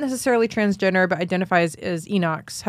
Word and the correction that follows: eunuchs: Am I saying it right? – Eunuchs. – Eunuchs.